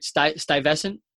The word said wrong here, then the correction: Stuyvesant: a different emphasis, Stuyvesant champagne.